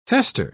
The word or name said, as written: fester